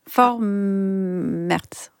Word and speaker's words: Vormärz: Vormärz.